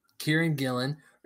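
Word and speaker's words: Kieron Gillen: So my book club pick this week is Die, written by Karen Gillan. Am I saying it right? – Kieran Gillan, and written by Stephanie Hans, lettered by Clayton Cowles, and designed by Ryan Hughes.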